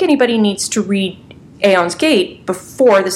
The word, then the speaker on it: Aeon's Gate: Am I even pronouncing that right? – Yeah, that's right. – Yes! For once I've gotten the pronunciation. So I didn't have a chance to read Aeon's Gate just because of availability up in Canada.